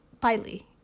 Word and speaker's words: Pili: They posted on Facebook at this point that people were making their way to higher ground, specifically to an area called Pili, P-I-L-I. – Okay. – I think that's how you pronounce it. – That sounds right.